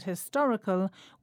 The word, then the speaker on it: historical: We pronounce historic and historical with the stress on the same syllable in both words, the to.